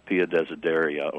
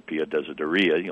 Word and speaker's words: Pia Desideria: The Lutheran pietism is typically dated from the publication of Spener's little book, Pia Desideria, or Pia Desideria, you'll hear it pronounced both ways, which was originally a preface, if you will, to a collection of Johann Arndt's sermons that was published in the year 1675.